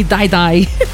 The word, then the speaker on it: Dai Dai: But I don't know about you, but if I hear someone tell me Dai Dai, I don't know. I don't want to die. No, anyway. But apparently it's spelled D-A-I-D-A-I.